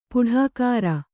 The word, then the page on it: ಪುಢಾಕಾರ: puḍhākāra